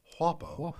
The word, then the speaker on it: Whappo: Wh- say it like Hank Hill. – Whappo.